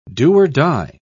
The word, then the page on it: do-or-die: British English